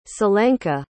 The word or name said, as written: selenka